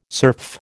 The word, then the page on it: serf: American English